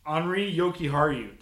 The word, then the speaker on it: Henri Jokiharju: Henri Jokiharju.